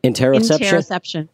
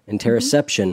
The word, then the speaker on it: interoception: Interoception. – Interoception mm-hmm.